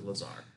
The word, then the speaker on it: Lazar: Yes, I use the word bizarre because it rhymes with Lazar.